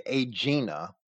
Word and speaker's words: Aegina: While Ahigena, as I would pronounce it, or some people might say Aegina, and that's a name similar to the name of the Sea, but it's Aegina in our edition of Rawlinson's Herodotus.